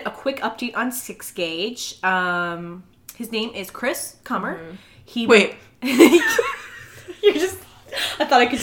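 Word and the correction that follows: Kummer: Are you sure it's pronounced? Wait, it's really Chris Comer? – Maybe it's Coomer. It's K-U-M-M-E-R. – So it's probably Coomer. Okay. Chris Coomer.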